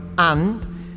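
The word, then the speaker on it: Anne: La primera es una serie de, Netflix que se llama And con una E. Eh, se escribe A-N-N-E y es así, and con una E.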